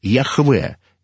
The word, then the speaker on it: Яхве: И там уже стоит не «эхье, эшер, эхье», а всего лишь одно слово, которое филологи определили, что оно произносится как «Яхве».